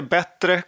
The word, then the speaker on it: B3: Eh, det här lilla konsultbolaget som envisas med eh, att man ska uttala namnet på, på något sätt. Jag säger B3 Eh, trots det. B3 Consulting. – Ja, de säger bättre consulting.